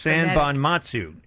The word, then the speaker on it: Sanbonmatsu: It's pronounced Sanbonmatsu, which is actually how it's spelled, Sanbonmatsu. It took me to third grade to learn how to spell that name, Sanbonmatsu. – You know, it's funny because I think I was close on that, and I was going to say it, I didn't want to butcher it. So it's Sanbonmatsu, the way it looks, basically.